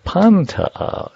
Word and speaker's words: Pantea: Pantea. Accent on the first syllable.